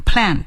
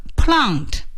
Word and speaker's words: plant: Plant 大家听出来 Alan 是美国人，读的是美式的发音 Plant。那要是第一个 Plant，那是英式的发音 Plant，无论是 Plant and Plant 都是正确的。